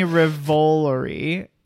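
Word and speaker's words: Revolori: Tony Ravioli. No. – Tony so Ravioli. You said in the theater. – I know. I didn't say Tony Ravioli. – Tony Rivolori, Josh Segarra, and Samara Weaving.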